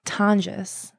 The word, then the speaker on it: Tonjes: Tonjes.